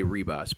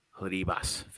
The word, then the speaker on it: Ribas: I always used to say Ribas, but you don't pronounce that R, I guess. You know, I used to be a big Amanda Ribas fan, especially when she was coming up.